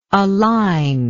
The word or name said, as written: aliner